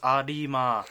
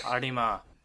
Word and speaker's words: Arima: First, Arima, seriously, pronunciation, it's Arima. Arima.